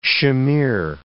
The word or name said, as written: chimere